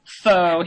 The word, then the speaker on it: pho: Pho, which I pronounce pho, but I've learned it's pronounced pho.